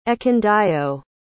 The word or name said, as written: Ekundayo